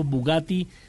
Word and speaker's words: Bugatti: Pues bien, ahora hay que decir que es uno de los 10 hombres que está buscando el famoso Bugatti 110, ¿así se pronuncia? Ajá.